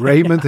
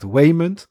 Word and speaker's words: Waymond: dat hij gewoon Raymond heet. Raymond. Maar, maar, kun je het niet goed uitspreken? Nee, ja, dus, dus, maar, maar hij heet gewoon echt Waymond. Waymond.